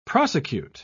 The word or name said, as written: prosecute